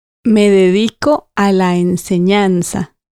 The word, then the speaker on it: me dedico: So me dedico, remember the stress on the I, means I dedicate myself.